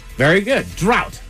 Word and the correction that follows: draught: D-R-A-U-G-H-T. – Yeah. Why is that hard to say? – Drought? – Actually, no, it's draft. – Draft. So there's a silent F. – D-R-A-U-G-H-T.